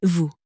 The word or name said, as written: vous